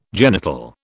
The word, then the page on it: genital: British English